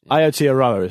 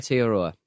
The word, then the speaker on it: Aotearoa: Aotearoa is how it's pronounced, I think. – Aotearoa.